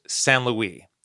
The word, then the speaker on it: Saint-Louis: It's spelled like St. Louis, but it's pronounced Saint-Louis, and it's actually a UNESCO World Heritage Center.